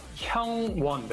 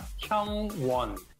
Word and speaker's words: Hyungwon: De Monster X Hyungun. Ajá. Se pronuncia Hyungwon, ¿verdad? Hyungwon. Esto tal vez es un ah. poco difícil porque este sonido no existe en español. Entonces se pronuncia Hyungwon.